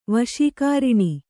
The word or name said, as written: ವಶೀಕಾರಿಣಿ